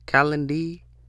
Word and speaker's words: Calendly: But there's a thing called you know how you have calendar, but I think it's called Calendy. It's like calendar, but I think it's like, let me see, can I find it? Yeah, Calendy. Calendy is spelled Calendly.